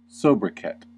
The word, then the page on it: sobriquet: American English